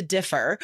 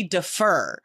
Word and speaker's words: differ: And then one of the students was like, that's not how you say it, because I've heard native speakers say defer. And I'm like, well, that's a different word. Like, that's an entirely different word. Differ, syllable, first syllable is stressed, and defer, that's like a, it's a different verb.